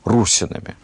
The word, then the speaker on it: русинами: Иногда называем их русинами. Хотя правильнее было бы с точки зрения древнерусской грамматики и фонетики, наверное, с ударением на первый слог – русинами.